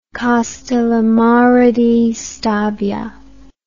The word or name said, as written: Castellammare di Stabia